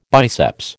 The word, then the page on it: biceps: American English